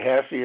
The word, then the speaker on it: Hasse: Okay, Hassey is spelled Hasse.